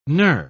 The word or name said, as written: knur